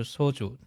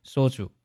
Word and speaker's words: 소주: 那么这个单词其实第一个字，第一个字正确读法呢是 소，松音，对吧， 소 주，所以它的标准发音呢是 소 주，但是呢生活当中一般呢读作紧音 소 주。